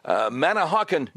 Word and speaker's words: Manahawkin: Let's go to Ryan, listening on WABC from, I hope I'm saying this right, Manahawkin, New Jersey. Did I say it right? – Correct.